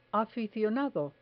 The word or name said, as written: aficionado